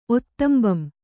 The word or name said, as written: ಒತ್ತ್ತಂಬಂ